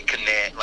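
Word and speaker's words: Knie: Certain things you have to pronounce, like they say Kne, like the word knee is German, you know, it's Kne in German, Kne, they pronounce every letter. So if you see a K in the letter, you pronounce it, you know, it's not silent, you know, so they don't say knee, it's Kne, you know, and what's another word that has a?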